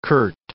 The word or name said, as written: curt